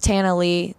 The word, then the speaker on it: Tana Lee: People call me Tana Leah, and that's just fancy. I'm from Texas. It's very plain. It's like Montana without the Mon, and Lee has a silent A. So it's just Tana Lee, real country.